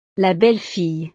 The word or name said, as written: la belle fille